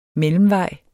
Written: mellemvej

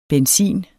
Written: benzin

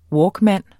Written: walkman

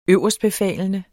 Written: øverstbefalende

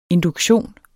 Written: induktion

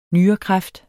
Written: nyrekræft

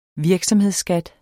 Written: virksomhedsskat

